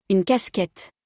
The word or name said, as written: Une casquette